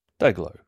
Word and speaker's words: DYGL: And I'll start out with Dayglow, D-Y-G-L, pronounced Dayglow, an all-Japanese crazy talented band, but tell me they don't sound Australian.